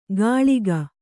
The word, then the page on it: ಕಾಳಿಕ: kāḷika; kannada